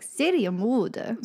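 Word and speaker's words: Serienmörder: You have to say it like Syrian Morder.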